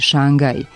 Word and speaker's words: Šangaj: Teniski turnir nije u Šangaju zato što je u vlastitoj imenici Šangaj. Kratko uzlazni akcenat na prvom slogu, a na sljedećem je dužina, što u promeni, umjesto dužine, na drugom slogu daje dugo uzlazni akcenat i izgovor Šangaju.